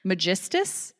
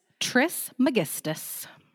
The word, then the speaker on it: Trismegistus: Magistus? – Trismegistus.